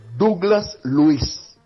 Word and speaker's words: Douglas Luiz: Allora, la pronuncia è Douglas Luiz. – Grazie, abbiamo risolto. Allora, ci avevo quasi azzeccato all'inizio, no? Perché Douglas è come Douglas Costa, quindi avevo imparato quello. Su Luiz o Luiz avevo il dubbio dell'accento, me l'hai tolto.